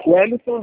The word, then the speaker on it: Wellington: Wellington da Silva Andrade.